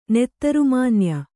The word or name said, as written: ನೆತ್ತರುಮಾನ್ಯ